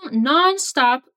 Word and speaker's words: nonstop: And he continues with You've been studying nonstop lately. Nonstop means constantly, without pauses. Repeat. You, you've been studying. You've been studying nonstop. Watch out for the pronunciation. Non-stop.